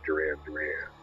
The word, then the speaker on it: Duran Duran: Now, in the movie, there was a D on the name of each Durand, but that was the French pronunciation. That's how the French said it. So they dropped the D, and it just became Duran Duran.